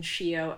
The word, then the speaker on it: Shio: And I kind of flip-flopped back and forth because I've been Shio, S-I-O, my whole life here in the States.